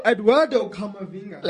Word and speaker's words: Eduardo Camavinga: So Eduardo Camavinga.